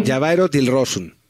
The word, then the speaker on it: Javairo Dilrosun: Javairo Dilrosun.